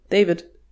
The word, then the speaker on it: Deywid: Aber ja, deswegen, also der wird genauso geschrieben, D-E-Y-W-I-D, David. – Aber gut, die Aussprache war mir jetzt neu, aber tatsächlich scheint das gar nicht mal so selten zu sein.